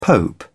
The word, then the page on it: pope: British English